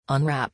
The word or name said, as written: unwrap